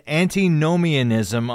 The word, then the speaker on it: antinomianism: You talked about legalism on the one hand, and then antinomianism on the other.